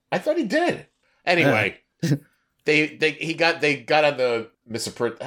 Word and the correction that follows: Mifepristone: How do you say that? – Mifepristone. – Mifepristone.